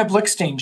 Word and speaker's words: Blikshteyn: Dina Blickstein.